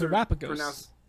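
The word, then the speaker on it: Terapagos: We have Ogerpon and Terrapagos. Sure, those Terrapagos. – Those are pronunciations. – Like Galapagos. That one. I still butchered it, but yes.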